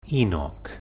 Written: Enoch

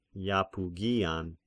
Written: Iapygian

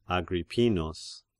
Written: Agrippinus

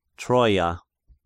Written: Troia

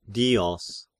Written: Dios

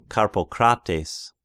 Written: Karpocrates

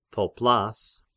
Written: Poplas

